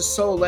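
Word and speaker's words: Soule: And then I believe the main, main act, if you will, is I'm going to pronounce it Sole, S O U capital L E and the Super Band.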